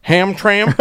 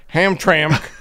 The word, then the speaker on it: Hamtramck: But Jim has written in to say, in case you need to cover events there again, the city of Hamtramck is pronounced as if there is a vowel in the last syllable, as in Hamtramck, with emphasis on the middle syllable.